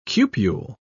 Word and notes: cupule: British English

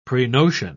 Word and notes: prenotion: British English

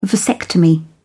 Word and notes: vasectomy: British English

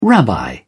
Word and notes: rabbi: British English

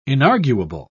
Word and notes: inarguable: British English

